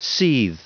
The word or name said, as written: seethe